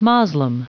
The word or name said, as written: moslem